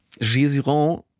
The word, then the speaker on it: Jusserand: Jusserand ist ursprünglich jüdisch, kommt aus Polen und wurde Jeschuran ausgesprochen. Mhm. Und dann im Französischen wird das Jusserand ausgesprochen. Und genau dasselbe, was du gerade gesagt hast.